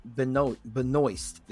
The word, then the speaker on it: Benoist: One to Nick Ospazak at UFC 105, Sean Pearson at UFC 124, and Lance Boyle. I could never pronounce that last name. Lance Benoist.